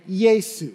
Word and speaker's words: Yeshua: You see it in the Bible, Yeshua in Hebrew, as it journeyed into Greek context, became Yesu, because it was the way they'd pronounce it.